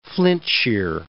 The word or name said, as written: Flintshire